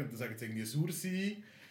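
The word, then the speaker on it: Sursee: Sursi. – Hallo. Sursi. – Sursi, Sursi. Ja, Sursi ist richtig gesagt.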